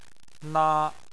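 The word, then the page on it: na1: tone 1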